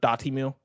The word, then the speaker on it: Dotemu: It seems like everybody is agreed on dot emu is like the separated pronunciation people give. Dot emu.